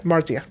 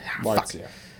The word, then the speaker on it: Marzia: Marcia. – Fuck. – M-A-R-Z-I-A. Marcia.